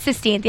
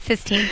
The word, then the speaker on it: Sistine: It's Sistine, yeah, I said it right, okay. You went up back in the day, you used to think it was 16th, but that's a lot of people do that, but it's not, it's Sistine, that's weird.